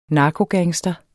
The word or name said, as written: narkogangster